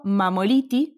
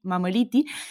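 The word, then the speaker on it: Mammoliti: Estamos como arroba PSI de psicóloga, Mamoliti, es eh, mi apellido, quizás se escribe con doble M en el medio, sí, Mamoliti,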